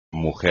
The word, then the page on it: mujer: espagnol